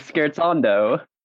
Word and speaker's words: scherzando: What do you think of when we say the word pronounced correctly, which is Scherzando? – Music.